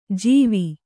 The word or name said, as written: ಜಿವಿ